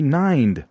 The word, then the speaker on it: Nind: It rhymes with mind. Oh, this is Angie Nind.